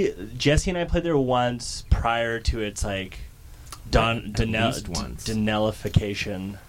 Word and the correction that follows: Donnellyification: Because is that how you say it? – Donnellyification. – Donnellyification.